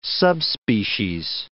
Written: subspecies

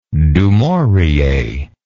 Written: du maurier

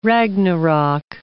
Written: Ragnarok

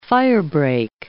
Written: firebreak

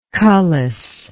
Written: cullis

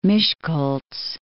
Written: Miskolc

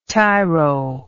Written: tiro

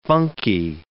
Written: funky

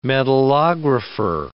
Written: metallographer

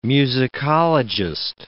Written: musicologist

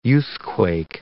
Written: youthquake

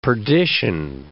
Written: perdition

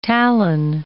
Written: talon

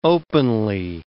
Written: openly

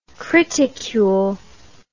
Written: criticule

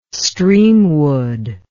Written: Streamwood

